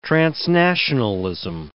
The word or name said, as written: transnationalism